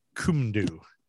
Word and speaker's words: Cwmdu: Michael Onufrejczyk, he's known as the Butcher of Kumdu. The town is CWMDU. It's Welsh.